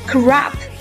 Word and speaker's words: crop: 英式读法是 crop，而美式读法是 crop。